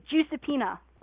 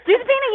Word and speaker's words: Giuseppina: So my real name is Giuseppina. There you go. Exclusive. – Okay, but that's probably the only time I'll be able to say it right. Giuseppina. – Giuseppina, you did it.